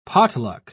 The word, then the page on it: potluck: British English